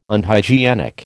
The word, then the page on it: Unhygienic: American English